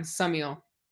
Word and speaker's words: Summiel: Before I pronounce it wrong, literally the entire podcast, and for everybody that's listening, is it Summiel or Summiel? – You said it perfect the first time, Summiel.